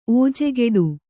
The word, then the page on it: ಓಜೆಗೆಡು: Kannada